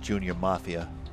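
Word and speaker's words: Junior M.A.F.I.A.: Junior Mafia. – It's M period, A period. – It's pronounced Junior Mafia.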